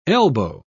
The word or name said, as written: elbow